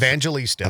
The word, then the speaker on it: Evangelista: I can never pronounce Evangelista.